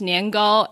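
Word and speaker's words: niangou (年糕): So, yeah, so for that one, in Mandarin, it's niangou, and it's the same pronunciation as year and high.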